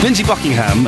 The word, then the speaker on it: Lindsey Buckingham: Lindsey Buckingham, and that's how it's pronounced, by the way, is an absolute fruitcake.